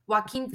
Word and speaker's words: Joaquin: And then on top of that, just in his personal life, like Joaquin, how do you say his name? Oh my gosh. – Joaquin Phoenix just seems like very unstable anyway.